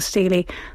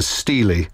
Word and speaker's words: stele: the stele, as I gather it's pronounced, the stele and the Code of Hammurabi, and it's quite astonishing how clear the cuneiform script is and the image that you described.